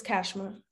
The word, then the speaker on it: Cashma: Yes, it's Cashma.